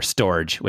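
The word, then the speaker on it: Storj: And I've been using one of them, the one you referred to earlier, Storage, which is pronounced storage, but it's spelled with a J.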